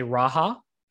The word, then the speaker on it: Raja: It, depending on where the person is from in the world, they will pronounce my name, whatever is. So like people from your part of the world will say Raya. People from Latin American countries will say Raja.